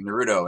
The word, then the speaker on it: Naruto: I had to watch it something, Naruto, and it's like, oh, that sounds like a Naruto.